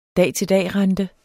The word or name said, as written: dag